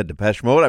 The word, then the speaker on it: Depeche Mode: Yeah, Depeche Mode.